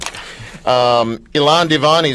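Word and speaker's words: Elan Divon: By the way, am I really pronouncing that right? Ilan yes. – Divan? – Yes, Ilan Divan, but you can call me Steve.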